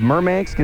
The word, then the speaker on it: Murmansk: Now, you came from a town called Murmansk.